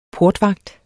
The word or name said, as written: PORTVAGTS